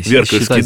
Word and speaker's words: Веркольский: Веркольский, да. – Потому что деревня Веркало, и, значит, да, ударение Веркольский монастырь.